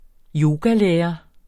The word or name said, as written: yogalærer